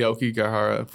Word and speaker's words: Aokigahara: The Aokigahara Forest.